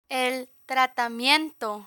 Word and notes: el tratamiento: Spanish

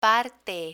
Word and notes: parte: Spanish

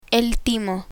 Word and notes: timo: spanish

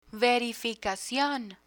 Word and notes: verificacion: Spanish

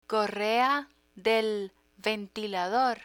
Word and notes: correa de ventilador: Spanish

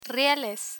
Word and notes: rieles: spanish

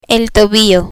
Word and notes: tobillo: spanish

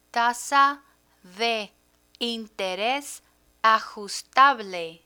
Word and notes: tasa de interes ajustable: Spanish

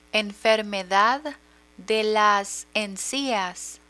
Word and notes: enfermedad de las encias: Spanish

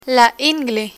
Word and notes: ingle: Spanish

